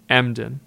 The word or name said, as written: Emden